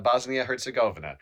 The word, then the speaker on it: Bosnia-Herzegovina: And Bosnia-Herzegovina.